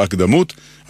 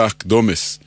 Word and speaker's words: הקדמות: אמרנו שהפיוט נקרא הקדמות, על שם מילת הפתיחה שלו, אבל כבר שמעתי מבטאים זאת הקדמות, כביכול צורת ריבוי. חלק מהטעות קשור לשיטת היידיש, שהרי היידיש הדגישה לא את העברה האחרונה, אלא את שלפני האחרונה. לא הקדמות, אלא הקדומס. מנסיבות שההברה האחרונה לא הודגשה, התעמם הצליל המדויק שהיה בה. במקום צרות אמרו צורס, במקום בהמות בהמס, ולהבדיל במקום הקדמות אמרו הקדומס.